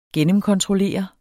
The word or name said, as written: gennemkontrollere